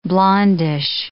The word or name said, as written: blondish